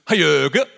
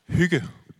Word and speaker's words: hygge: So I just picked my favorite, which was Hayurga. Hayurga. Is that right? No? What should it be? Hygge. Hygge. Hygge. Hygge. That's actually equally as satisfying to say. Hygge.